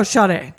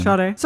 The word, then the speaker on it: Sade: Oh, Sade. Sade? Do you say it with a kind of a I think you said it right the first time. – Sade.